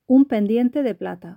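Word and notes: un pendiente de plata: Spanish (Spain)

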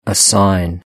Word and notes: a sign: British English

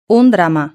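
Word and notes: un drama: Spanish (Spain)

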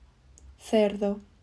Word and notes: cerdo: Spain

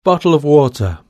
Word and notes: bottle of water: British English

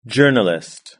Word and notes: journalist: British English

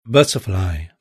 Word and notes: butterfly: British English

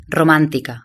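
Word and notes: romantica: Spanish (Spain)

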